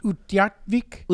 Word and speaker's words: Utqiagvik: Utqiagvik.